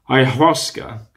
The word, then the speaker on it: ayahuasca: I'm not sure of the pronunciation. Ayahuasca, A-Y-A-H-U-A-S-C-A, ayahuasca, I would presume it is pronounced,